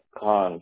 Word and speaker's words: Khan: Last name is Khan. K-H-A-N. – K-H-A-N.